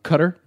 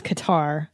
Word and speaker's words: Qatar: When you're in Qatar, they go, it's Cutter. I'm pretty sure it's not pronounced Cutter. – Well, the journalist kept saying Cutter. – Qatar. I think it's Qatar. Like that? Like Qatar. But not Cutter.